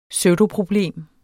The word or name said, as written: pseudoproblem